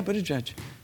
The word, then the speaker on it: Buttigieg: Buttigieg. – Buttigieg, is that how you say it? – Yeah, Buttigieg. Yeah.